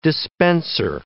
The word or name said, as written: dispenser